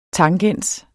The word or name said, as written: tangens